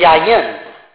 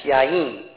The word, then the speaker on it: yayin: It is the word yayin, sometimes pronunciated in the sentence yayin.